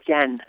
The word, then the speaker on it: Jen: I'm having the pleasure today of interviewing Jean Sincero. Did I say that correctly, Jean? – Definitely Jen. Jennifer. – Jennifer, yes.